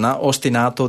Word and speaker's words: ostinato: ostinato that you can keep there, and then you can move just the bass. – Wait, say that again. – Ostinato. – That's the actual pronunciation.